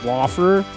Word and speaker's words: Lauffer: It's like, it's Laffer, but I get like Laffer. – How's it spelled? – L-A-U-F-F-E-R. It's very German.